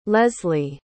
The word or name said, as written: leslee